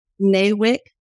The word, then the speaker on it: NAWIC: You were named a Nashville Business Journal Women of Influence president of, is it NAWIC? Am I pronouncing that correctly? – NAWIC.